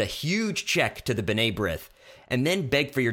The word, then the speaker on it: B'nai B'rith: I'd also suggest he learn how to pronounce it and say B'nai B'rith, but that's just me.